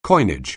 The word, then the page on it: coinage: anglais